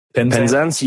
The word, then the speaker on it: Penzance: Am I saying that right? – Penzance? Yep. It's the far southwesterly tip, Land's End.